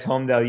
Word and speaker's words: Holmdel: Is it Holmdel? – Yes, Holmdel. You nailed it.